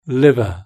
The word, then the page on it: liver: British English